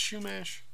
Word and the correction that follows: Chumash: I feel like I've heard the word Shoemash before. – Oh, it's a tribe? – Yeah. – It's the Chumash.